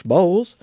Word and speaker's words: Bowles: The bowl, right? – Bowl, yeah. Bowles.